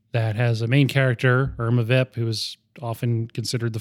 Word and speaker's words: Irma Vep: Also, I watched a movie a couple days ago called Irma Vep, which everyone who's French in the movie pronounces Irma Wep, whatever.